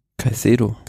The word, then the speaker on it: Caicedo: Kai Kedo? Caicedo. Caicedo äh, sich natürlich mit diesem Sieg geholt von Giovanni Visconti.